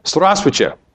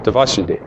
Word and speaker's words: здравствуйте: Здравствуйте. Здравствуйте. Hello and goodbye in Russian. The first one, hello, is a three-syllable word.